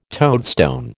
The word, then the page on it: toadstone: American English